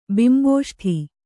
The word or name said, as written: ಬಿಂಬೋಷ್ಠೆ